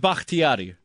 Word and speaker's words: Bakhtiari: David Bakhtiari.